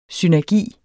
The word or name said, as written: synergi